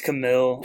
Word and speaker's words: Camille: But I think we should check out these clips of this trial, because Camilla, isn't that her name, Camilla? – It's Camille. – Camille, bro. – I don't want to say her name wrong, but I'm pretty sure it's Camille.